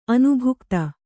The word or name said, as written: ಅನುಭುಕ್ತ